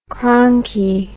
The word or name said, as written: cronky